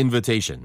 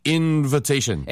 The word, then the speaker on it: invitation: It was on the invitation. – 맞습니다. 초대란 뜻도 있지만요. 이게 초대장이란 뜻도 있고요. 그래서 invitation. 발음 주의하세요. invitation이라고 안 들리고요. – invitation. – 예, invitation처럼 들려요. 태 액센트가 있기 때문에 하나 건너선 약해지거든요.